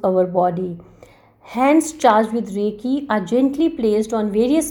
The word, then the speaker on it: Reiki: to the Reiki class. Reiki is pronounced as Rei Ki. It's a Japanese word, universal life force energy.